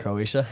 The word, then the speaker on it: Croatia: Yeah, they're either like, going against, what is that? Croata? How do you say that? – Croatia? – Oh. – No, we're good. It's Croatia.